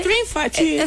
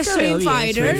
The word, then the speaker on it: Street Fighter: Street Fighter. – Street Fighter.